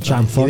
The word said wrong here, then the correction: Crawford: In realtà è scritto Crawford, quindi non è Chamford. – Quindi Crawford.